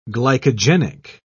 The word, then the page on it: glycogenic: British English